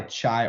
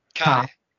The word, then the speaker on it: Chi: But BYU, you guys, you go by Chai Ultimate. I hope I'm saying that right. But Chai. – Almost. It's Kai.